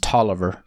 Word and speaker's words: Taliaferro: Well, I'll tell you the real shibboleth is if you can pronounce T-A-L-I-A-F-E-R-R-O. How is that pronounced? – Tallulah. – Tallifero? – That's... T-A-L-I-A-F-E-R-R-O is pronounced Tolliver. Tolliver County, Georgia. – Now that's interesting. – I stopped listening after the T-A-L and assumed you were asking about Tallulah Gorge.